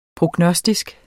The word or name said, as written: prognostisk